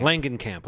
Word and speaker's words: Langenkamp: Is it Langenkamp camp or Langen? Is it a hard G? – I thought it was Langenkamp. – Langenkamp.